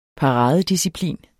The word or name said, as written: paradedisciplin